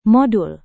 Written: modul

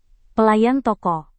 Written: pelayan toko

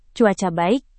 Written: cuaca baik

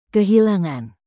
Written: kehilangan

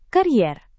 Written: karier

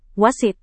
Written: wasit

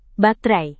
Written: baterai

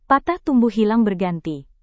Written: patah tumbuh hilang berganti

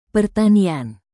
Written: pertanian

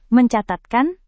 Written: mencatatkan

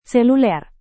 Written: seluler

